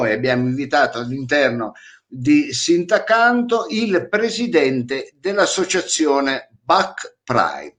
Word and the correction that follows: Bike: Bike, non Back. Si legge Bike?